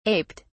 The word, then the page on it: aped: British English